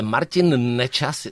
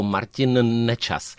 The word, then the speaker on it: Martin Nečas: Ale Martin Nečas se vlastně jako hůř vyslovuje, že musíš dvakrát říct N, jako Martin Nečas.